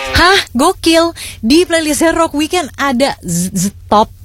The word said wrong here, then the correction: ZZ Top: Bacanya gimana sih? ZZ Top, sebuah band hard rock dari Houston, Texas nih.